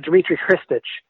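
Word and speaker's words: Dmitry Khristich: My understanding in Russian names is when you have a KH, it's kind of like a, but like a lighter, you know? So like Dmitry Hristich.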